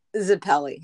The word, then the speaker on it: Zappile: Zappile.